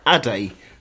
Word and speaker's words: Aday: Okay, and his real name is Michael Lee Aday. Surname is spelled A-D-A-Y. So Aday, Aday. I'll leave it up to you to decide.